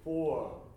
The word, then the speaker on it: ford: はい、それで今発音的にあの Ford っていう言葉の R の音がちょっとやっぱり聞こえてこないので、 Ford じゃなくて Ford、 Ford、舌が上にちょっとグッと動いた状態を意図的に Ford、 Ford、この F って音を必ず一瞬入るようにイメージしていただけたらと思います。